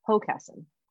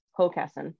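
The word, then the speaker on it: Hockessin: So Charlie was the closest with the most commonly mispronounced variety of Hawkesson, like rhymes with moccasin. It's actually Hawkesson. And it is where I live, Hawkesson, Delaware.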